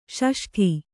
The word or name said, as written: ಷಷ್ಠ